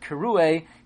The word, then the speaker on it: Kirue: However, the word Kirue, even though it's read Kirue, it's spelled Kirie.